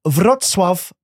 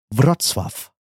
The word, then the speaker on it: Wrocław: We got police officer Matt, Mike in Wrocław, Poland.